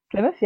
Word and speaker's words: Plymouth: Based in Plymouth, I think that's how you pronounce the name, right? – Plymouth, yeah. Plymouth.